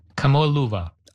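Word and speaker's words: Kamoa-Luva (Kamoʻoalewa): The space rock is a Kamoa-Luva? How do you say it? – Yeah, yeah. Kamoa-Luva.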